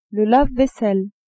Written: lave-vaisselle

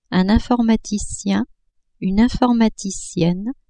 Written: informaticien-informaticienne

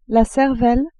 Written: cervelle

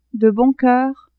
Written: de bon coeur

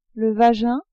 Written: vagin

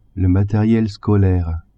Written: materiel scolaire

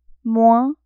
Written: moins

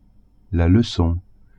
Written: lecon

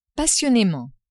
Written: passionnement

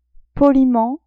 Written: poliment

